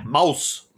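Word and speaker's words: Maus: or I don't know if that's how you say it, Mouse. It's M-A-U-S. – Yeah, Mouse.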